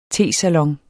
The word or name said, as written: tesalon